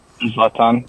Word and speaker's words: Zlatan: Is that how you say his name, Zlatan? – Zlatan. Yeah. When Zlatan came, he was still...